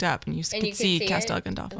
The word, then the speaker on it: Castel Gandolfo: Did I say that right, Italiano? – Castel Gandolfo. – Castel Gandolfo. Castel I always Gandolfo. Think of Gandalf.